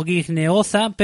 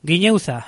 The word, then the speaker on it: Guineoza: Escrito es algo así como Guineoza, pero se pronuncia, según nos dicen, Guineuza.